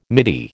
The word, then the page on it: midi: American English